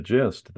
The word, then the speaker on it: gist: that it just reminded me when you said the gist. That is a word. Gist, of course, is a word with a soft G at the beginning, which you don't know where I'm going with this.